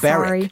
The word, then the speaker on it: Berwick: Someone's texted in to say the W in Berwick on Tweed, where the pilgrimage ends, is silent. So it's pronounced Berwick.